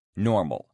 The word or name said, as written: normal